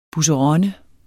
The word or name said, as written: busseronne